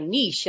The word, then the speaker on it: niche: I took a Twitter poll the other day and everybody else said, you know, niche as in rhymes with quiche.